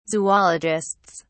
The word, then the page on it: zoologists: British English